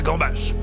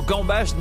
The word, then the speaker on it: gambas: Sachez en outre que le mot gambas n'existe pas au singulier. Oui, il y a un S final obligatoire qui se prononce comme pour nous rappeler qu'il faut les bouffer à gogo.